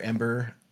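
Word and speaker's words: Em8er: He starts Ember, which he is spelled EM-8ER, Ember, M8er.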